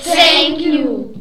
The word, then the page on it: thank you: english